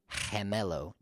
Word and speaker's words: gemelo: First of all, G followed by E or G followed by I is going to sound like H. So we have words like gemelo, G-E-M-E-L-O, gemelo, which means twin.